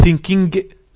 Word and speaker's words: thinking: خلاص يا اختي thinking كده كده حرف الجيم ما تنطقش خالص اهوت.